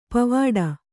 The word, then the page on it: ಪೋವಿಡು: pōviḍu